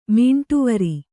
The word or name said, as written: ಮೀಂಟುವರಿ